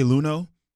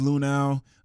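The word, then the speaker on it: Luhnow: It's much easier to say Luno, but the text line's telling me it's Lunau.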